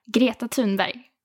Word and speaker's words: Greta Thunberg: So in proper Swedish accent, how do we say that? – Greta Thunberg. – Greta Thunberg.